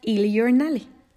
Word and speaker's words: Il Giornale: o como en italiano se pronuncia mejor, Il Giornale, o similar a eso.